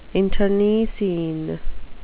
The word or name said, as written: internecine